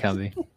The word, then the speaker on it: Cumbie: You get the impression that he showed up and he had this really good pitch where uh, Sonny, so, so how do you say it, Sonny Cumbie? Um, Sonny like, Cumbie.